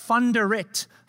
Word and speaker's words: Van der Riet: Now, don't start calling me Van der Riet from now on because I'm American now and Van is fine because it's spelled V-A-N. But in South Africa, V-A-N is pronounced Fun.